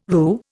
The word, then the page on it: roo: Indian